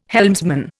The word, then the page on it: helmsman: Indian